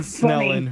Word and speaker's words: Snellen: It's named after Herman Snellen, who's a Dutch ophthalmologist.